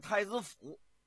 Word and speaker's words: 太子坞: 你比方说，在这个河北省啊，廊坊的西边有个地方，叫这个太子坞。但是你要说太子坞啊，也不是不行。可是啊，他们一般的当地啊，都叫太子府。说这个字是多音字吗？不是，就这么念。